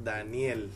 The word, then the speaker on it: Daniel: Well, as you say, my name is Daniel. The Spanish pronunciation would be something like Daniel.